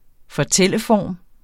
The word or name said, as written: fortælleform